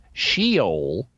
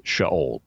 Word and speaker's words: Sheol: Now, you'll hear some people mispronounce it as Sheol, but it's not. In Hebrew, it's Sheol.